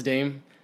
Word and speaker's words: Dam: D-A-M space Funk. – Okay. Mm-hmm. Oh, so Dame Funk, but like yeah. It's pronounced Dame.